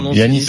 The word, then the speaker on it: Giannis: Oui, mais en grec, ça se prononce Giannis. – C'est un prénom grec.